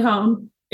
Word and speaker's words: Hoehn: Charlie Hohn. It's spelled H-O-E-H-N, but it sounds like zone.